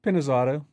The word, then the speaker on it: Pinizzotto: Am I saying this right? – Pinizzotto. – Pinizzotto. That's Italian? Italian? – Yeah. Yes, it is.